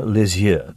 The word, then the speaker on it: Lisieux: But you know, Lisieux, that's how you say it.